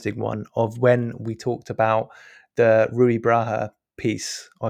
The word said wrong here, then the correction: Braga: You can't pronounce his surname either. Rui Braga.